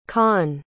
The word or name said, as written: khanh